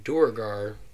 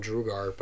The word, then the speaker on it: Duergar: the Duergar, which I've heard people call them Drugar, but I don't understand that pronunciation, and then last is the Drow.